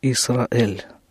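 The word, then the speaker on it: «Исраэль» (ישראל): На иврите это произносится «Исраэль».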